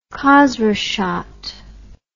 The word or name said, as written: khozraschot